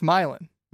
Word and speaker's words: Milan: First off, I think it's Milan. – Milan. Why did I say Milan? – You of all people do not strike me as a type to get that pronunciation right in the way that it's currently said in Europe.